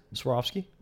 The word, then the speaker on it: Swarovski: Is that how it's pronounced? Or Swarovski? – Oh, yes.